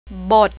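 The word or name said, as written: บท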